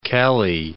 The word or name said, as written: Kelly